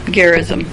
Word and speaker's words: Gerizim: how do you say that? – Gerizim.